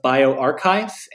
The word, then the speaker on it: bioRxiv: There's been a lot of preprints that are coming out of BioArchive, and it's spelled like B-I-O-R-X-I-V.